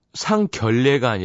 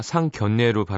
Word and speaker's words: 상견례: 최우진 씨, 상견례는 상결례가 아니라 상견례로 발음하는 게 맞는 거구나.